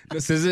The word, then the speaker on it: SZA: And this story broke out a few weeks ago when SZA. Did I pronounce it right? – Yep. – Yes. Yes, you did. – Tell him how you pronounce her name. – SZA is right.